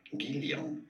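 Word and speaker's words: Guylian: In French, it is said as Guillon.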